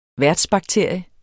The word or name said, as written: værtsbakterie